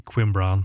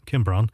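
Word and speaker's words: Cwmbran: And next up, it is going to be Mogwai, and tricky word to spell, and had to be I think the correct pronunciation is actually Quimbran, but say Kimbran.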